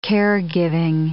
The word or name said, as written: caregiving